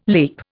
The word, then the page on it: leap: Indian